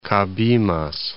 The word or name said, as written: Cabimas